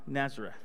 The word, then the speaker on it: Nazareth: And Jesus, well, that's a hard word for a Hispanic, Nazareth. There you go, I said it right.